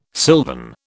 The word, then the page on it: sylvan: British English